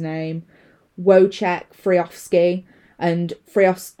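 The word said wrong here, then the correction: Frykowski: no, it's Frakowski,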